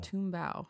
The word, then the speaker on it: Tumbao: Tumbao. How do you spell it? T-U-M-B-A-O.